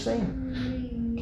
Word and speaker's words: Sejm: They pronounce it the Polish Sejm, like the equivalent of like the parliament. They were representatives of S-E-J-M.